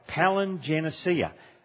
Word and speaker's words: palingenesia: It's the word, say it right, palingenesia.